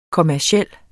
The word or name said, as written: kommerciel